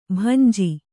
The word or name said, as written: ಬಂಜೆ